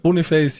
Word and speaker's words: Boniface: Wird der so ausgesprochen? Boniface, ja? – Boniface, ja, habe ich jetzt gelernt.